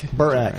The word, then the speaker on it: Burak: So uh, so Pete Burek is uh, he a uh, Bur- Burak. – now you made me question how I say your name. – It's like Burr and then Ack. Burak.